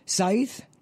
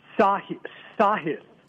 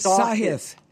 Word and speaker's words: Sahith: Now, his name is pronounced Scythe? – Sahith. – It is Sahith.